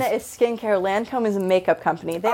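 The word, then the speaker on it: Lancôme: Is it Lacombe? – Lancome.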